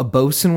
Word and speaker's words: boatswain: Like a boatswain whistle? – A bosun whistle. – Whoops. Oh, I said it wrong.